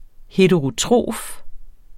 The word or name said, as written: heterotrof